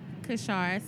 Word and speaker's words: Kashara: Kashara Smith, K-A-S-H-A-R-A, Smith, S-M-I-T-H.